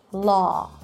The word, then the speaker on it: law: And this is a really good example because although you have a W there, right, law, we do not round our lips to make a W sound.